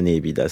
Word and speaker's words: nepita: Mais nous, on dira que c'est la nébida, ça ne ressemble à rien d'autre. – Ça s'écrit N-E-P-I-T-A.